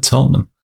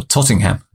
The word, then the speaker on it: Tottenham: Ozzy Ardiles did it at Tottenham, or Tottingham, as he would have said. – Tottenham. – Tottingham Hotspur. That's how good old Ozzy pronounced it.